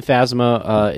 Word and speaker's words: Phasma: No, but they have the Star Wars stuff where I know they also showed, is it Captain Phasma? Is that how they pronounce it? – Yeah, Captain Phasma is played by, I can't remember her last name.